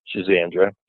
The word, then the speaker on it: schizandra: So anyway, schisandra is like a super, super cool herb. It's spelled S-C-H-I-Z-A-N-D-R-A, and schisandra.